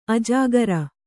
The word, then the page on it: ಅಜ್ಜುಗಾರ: kannada